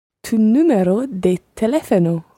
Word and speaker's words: número: Can you tell me anything about the spelling of número? – There's an accent on the U. – Yeah, because otherwise it would be número. – Número, which is wrong again.